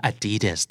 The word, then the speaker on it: Adidas: Adidas